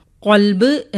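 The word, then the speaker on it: ഖൽബ് (قلب): അതുപോലെ ക എന്ന അക്ഷരത്തിന് രണ്ട് ഉച്ചാരണങ്ങൾ വരുന്നു. ഒന്ന് ക, ഒന്ന് ഖ. കൽബ് എന്ന് പറയുമ്പോൾ നായ എന്നർത്ഥം വരുന്നു. എന്നാൽ ഖൽബ് എന്ന് വരുമ്പോൾ അതിന് ഹൃദയം എന്നാണ് അർത്ഥം വരുന്നത്.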